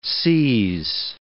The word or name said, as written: seise